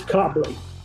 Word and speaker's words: Cobleigh: It's Cobleigh.